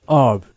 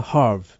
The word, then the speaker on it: herb: Herbal extracts、 herb、ハーブですね。イギリスでは herb、アメリカでは herb。と発音します。